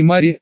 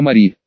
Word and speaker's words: Мари: «Не Мари, а Мари», — взмахнула собеседница ручками в акте недовольства. «Сами ведь спросили. Ох уж эти зимноградские заморочки. Ударение в имени, Это как серьга в правом ухе.